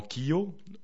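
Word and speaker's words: kio: Il y a également Kiyo, O comme chose, donc ça veut dire Kiyo, ça veut dire quoi.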